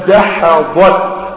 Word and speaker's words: دحضت: وأنا أرجو أن تنطق هذه الكلمة الآن: دحضت. دحضت. كل واحد من اللي قاعدين شاب ولا شباب يقولها بينه وبين نفسه: دحضت. طب ليه؟ عشان تتعلم كيف تنطق الدال مع الحاء مع الحاء مع الضاد بعد داح ضاد.